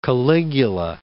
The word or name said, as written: Caligula